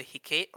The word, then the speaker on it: Hecate: It's called the Hecate, or it's spelled H-E-C-A-T-E.